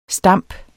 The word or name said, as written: stamp